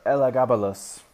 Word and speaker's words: Elagabalus: Mr. Elagabalus.